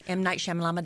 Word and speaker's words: M. Night Shyamalan: M. Night Shyamalan ding dong. – That is the correct pronunciation, yes.